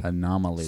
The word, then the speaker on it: Anomalie: Anomaly. – Spell it. – It's A-N-O-M-A-L-I-E.